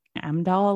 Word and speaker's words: Heimdall: H-E-I-M-D-A-L-L. Hemdall. – And the way that, now that you're saying that, I think the way they pronounce it tends to be a little bit more like Amdahl, like they don't necessarily hit the H very hard.